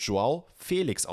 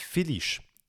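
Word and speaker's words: João Félix: Wichtig, Jungs, Mädels, der Mann wird Joao Felix ausgesprochen, nicht Filisch. Felix.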